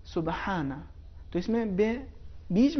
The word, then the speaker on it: سبحانہ: تو اس کا صحیح تلفظ سبحانہ، سبحانہ.